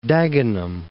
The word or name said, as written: Dagenham